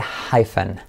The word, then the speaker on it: hyphen: Se dice hyphen, hyphen, ¿ok? Hyphen, como hyphen, alguien que se llame fen, hi, hi.